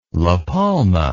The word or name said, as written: La Palma